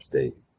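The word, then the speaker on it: Staite: How do you pronounce the name, by the way? – State. – Jewel. – I looked it up. – State? – Yep, State.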